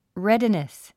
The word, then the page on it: readiness: rédinis; レェディネス